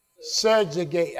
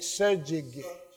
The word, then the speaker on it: subjugate: To surgeate, let me spell it for you, S-U-B-J-U-G-A-T-E. Subjugate. I say eight. Subjugate. Subjugate. Thank you.